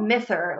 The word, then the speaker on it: Mither: Mithra. Like, I almost thought it was Mother of the Sea, but it's spelled M-I-T-H-E-R.